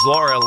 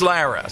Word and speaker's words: Lara: D-O-M. – Yeah, it is Lara. Sometimes we forget and we, you know, slur it. Bill, nicely played. It is Lara Trump.